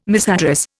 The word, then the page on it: misaddress: Indian